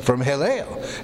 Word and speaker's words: Helel: That comes from Hillel, and so his name H-E-L-E-L, Hillel, long E pronounced A in from the Greek, is his actual name.